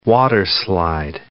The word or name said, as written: waterslide